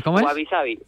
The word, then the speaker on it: Guavisabi: Vale, pues nutricionista deportivo, a ver si lo digo bien, de Guavisa Nutrición, ¿verdad? Guavisa Nutrición. ¿Cómo es? – Guavisabi. Es Guavisabi. – Ah, Guavisabi. Vale. – Es como parecido a wasabi, la palabra de japonesa, sí, del sí, alimento, sí.